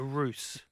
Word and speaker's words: ruse: A ruse. Is that R-U-S-E? – R-U-S-E, ruse. Where a ruse, there you go, good pronunciation.